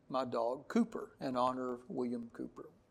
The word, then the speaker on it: Cowper: We have a little Yorkie, and his name is Cooper, spelled C-O-W-P-E-R, pronounced Cooper, but he was named after William Cooper, who wrote a couple of good hymns.